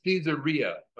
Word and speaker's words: Caesarea: Caesarea being named after Caesar, And my son has corrected me on how to pronounce Caesarea. It's Caesarea, okay? He listens to these books on tapes by these persons that know grammar better than I, and it's Caesarea, but I'm gonna have a hard time remembering that.